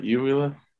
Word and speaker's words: uvula: I said that right, uvula? – Yeah.